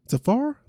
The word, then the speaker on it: Sephora: So she tells TMZ she was going to a Sephora store Safar, that's how you pronounce it, y'all.